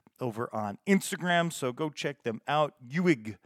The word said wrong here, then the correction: Ewig: E-W-I-G, which is Ewig. – Ewig. So yeah, not that I had that right. Very English, the way I pronounce that.